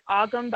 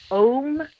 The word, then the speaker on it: Ogam: Ogam, O-G-A-M. Is that how you pronounce it? – Oh, yeah. Well, yeah, it looks like Ogam, but I think it's pronounced Om or Ogam. – Okay. – It really depends on who you ask, and also too, you know, holding no judgment, because I butcher words all the time.